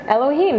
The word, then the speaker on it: Elohim: Elohim, right? Elohim.